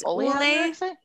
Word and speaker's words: Ole Henriksen: I got the Olin, I don't ever know how to say this. Olin Henriksen? – It's Ole Henriksen.